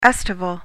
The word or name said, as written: estival